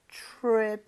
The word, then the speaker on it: trip: 어쩐지 자 T-R-I-P trip 자체가 에이. 여행이죠 발음할 때 트립 아니죠, 네? 여러분들. TR, 이 충돌을 하게 되면 치읓 비슷하게 됩니다.